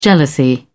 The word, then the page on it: jealousy: British English